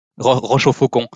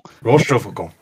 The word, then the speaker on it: Rochefoucauld: I feel like it's my duty to look after French pronunciation. Yeah. That is Rochefoucauld. – Rochefoucauld.